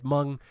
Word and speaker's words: Hmong: No, Mario, it's pronounced Hmong. – Please. I worked all day to make sure I got that pronunciation correct. I believe it's Hmong, right? The H is silent.